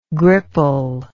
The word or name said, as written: gripple